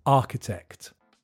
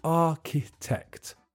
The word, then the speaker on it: architect: The answer is architect. The pronunciation is tricky because there's a ch in the middle that's just pronounced k, and the middle syllable is weak. So you say architect.